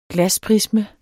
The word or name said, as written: glasprisme